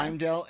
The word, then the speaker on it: Heimdall: How do you say his name? – I think it's Heimdall. – Yeah. Heimdall, and really the only thing I think he had one grunt, like in the whole episode.